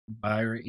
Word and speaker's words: Ebirah: Yeah, the Ebirah. Is that how you say it? Ebirah? Ebirah? – Ebirah, Ebirah, Ebirah yeah.